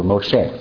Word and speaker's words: Moshe: Okay, that's how you say his name in Hebrew, Moshe.